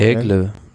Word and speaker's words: Aigle: Naja, und dann kommt die Etappe am Sonntag äh, von, wie spricht man das, Angers nach Châtel? – Aigle. Aigle?